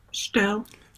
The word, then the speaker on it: Steyerl: by Hito Steyerl, and I may be doing wrong on the last name, so let me spell it, Steyerl. Is that right? – Steyerl. Hito Steyerl.